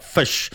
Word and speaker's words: Ghoti: Now they spelt fish a bit cleverly. It was G-H-O-T-I. So the G-H is like enough, I suppose, and initiate the T-I, so it pronounces fish.